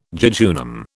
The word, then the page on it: jejunum: British English